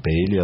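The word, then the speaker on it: Paley: И очень мне, и как раз мне хотелось посмотреть на младшую сестру Владимира Полея, на Наталью Полей, Натали Полей. Даже в произношении Пейли она потом была в Соединенных Штатах.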